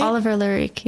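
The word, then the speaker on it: Oliver Laric: Am I pronouncing his name right? – Oliver Larik, yeah.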